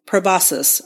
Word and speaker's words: proboscis: Like the, I'm not going to say it wrong. Proboscis. I did say it right.